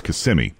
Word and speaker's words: Kissimmee: Some people put the emphasis on the first part, Kissimmee, but it's Kissimmee. Kissimmee. Kissimmee, Florida, near Orlando.